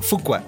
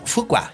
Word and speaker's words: Fuqua: ¿Por qué le cambiaste la pronunciación a Foucault? uh, Fuqua? Fuqua. es que Fuqua. ahorita se convirtió en Foca. Foca. Tú lo dijiste bien hace rato.